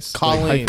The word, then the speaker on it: Colleen: Waleen Bollinger apology. I tried to, like, Colleen.